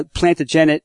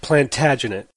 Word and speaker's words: Plantagenet: Now, Plantagenet... – It's pronounced Plantagenet.